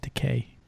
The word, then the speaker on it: Takei: It's pronounced Takei, just so you know.